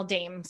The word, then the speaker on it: Daem: On Facebook, you can find me at Danielle Daem Coaching, and on Instagram at Danielle Daem.